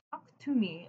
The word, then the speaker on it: Taaqtumi: So Talk to Me, it's spelled T-A-A-Q-T-U-M-I, is an Inuktitut word for in the dark.